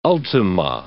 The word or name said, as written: ultima